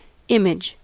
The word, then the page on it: image: American English